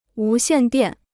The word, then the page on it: 无线电: wú xiàn diàn